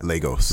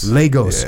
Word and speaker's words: Lagos: When he returned to Lagos, how do I say that? – Lagos. – Lagos.